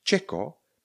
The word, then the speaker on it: checo: Čeko. Píšeme checo, vyslovujeme čeko.